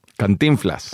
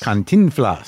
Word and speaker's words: Cantinflas: Let's talk about, how do you say it, Cantinflas? – Cantinflas. – Cantinflas. – There we go.